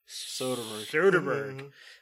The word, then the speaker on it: Soderbergh: Soderbergh.